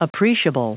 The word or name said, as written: appreciable